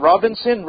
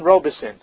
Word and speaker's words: Robison: James Robison, how do you say it? Robinson? Robison.